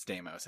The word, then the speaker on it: Deimos: Is it Deimos or did I say it wrong? – No, I think it's Deimos. I think Deimos. You're right.